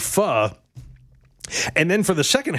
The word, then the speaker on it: pho: There is a Vietnamese soup that's spelled P-H-O. It's pronounced pho.